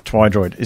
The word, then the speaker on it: Twidroid: Twidroid is how you pronounce that. – Did I say it wrong? – You did. – I'm sorry. – You said Tweedroid.